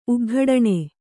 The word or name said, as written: ಉಗ್ಗಡಣೆ